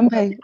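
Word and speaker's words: Ganbei (干杯): Das heißt einfach Ganbei und ich habe es mir für immer gemerkt. Stimmt das schon, ne? – Ja, perfekt. – Ausgesprochen. Ja, Ganbei.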